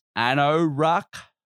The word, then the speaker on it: AnnoRaaq: It's Anoruck. I think it's a German pronunciation. So it's like A-N-N-O-R-A-Q.